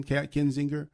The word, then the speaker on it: Kinzinger: And Adam Kinzinger, did I say it right? Mm-hmm. K-I-N, Kinzinger.